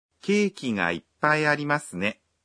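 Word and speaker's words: Иппай (いっぱい): Произношение «иппай» непростое. – «Иппай» содержит удваиваемый согласный звук. Когда вы произносите это слово, нужно на секунду задержать дыхание.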